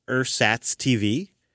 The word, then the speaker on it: Ersatz: And I came across an app that we've been debating how you pronounce it, but I think it's like Ersatz TV, E-R-S-A-T-Z TV.